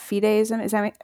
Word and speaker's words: fideism: It's interesting because the first kind of fideism, am I saying that correctly? – Yeah.